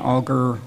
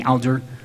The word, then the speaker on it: Alger: John Auger is the brother of the famous Fanny Auger, or as we generally mispronounce her name, Fanny Alger, who lived with the Smiths for some time.